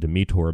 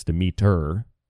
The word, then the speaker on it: Demeter: Goddesses like Demeter are also, I pronounce it Demeter, but it's Demeter, D-E-M-E-T-E-R, Demeter, is the goddess of the harvest, presiding over grains and fertility.